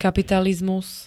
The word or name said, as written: kapitalizmus